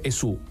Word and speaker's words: esu: E questa U c'è, insomma, in greco moderno tu si dice esi, si scrive con la Y, a Calimera si dice esu, scritto U si dice esu.